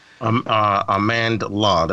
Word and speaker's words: Amandla: The performances in this movie, particularly Amanda Steinberg, if I'm saying her name correctly. – Amandla, there's an L.